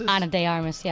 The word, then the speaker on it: Ana de Armas: Ana de Armas, yeah.